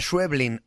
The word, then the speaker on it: Schweblin: Recibimos a la argentina Samantha Schweblin.